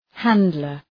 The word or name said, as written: handler